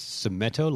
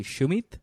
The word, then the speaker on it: Sumet: So you, it's Sumeto, like Geppetto? I say Sumeto because in English my name is Sumet, but it's an English pronunciation of a Hindi name, which would technically be Shumit.